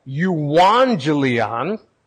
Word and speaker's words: euangelion: It's actually pronounced, let me check, euangelion, because it's Greek, but in our English transliteration, it's evangelizo or evangelon, and it means the good news.